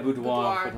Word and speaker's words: boudoir: What's the right word? I think it's boudoir. – Yeah, boudoir.